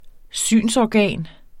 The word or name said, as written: synsorgan